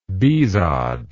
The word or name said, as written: bihzad